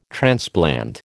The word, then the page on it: transplant: American English